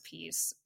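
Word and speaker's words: P1ece: It's like P one E C E, and you just mm. but you mm. pronounce it as peace.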